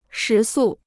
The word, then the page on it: 食宿: shí sù